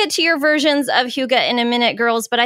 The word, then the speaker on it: Hygge: So if I may, Hygge is spelled H-Y-G-G-E. I think that's important. As you might see it out into the wild, you'll know exactly how to pronounce it. It is Hygge.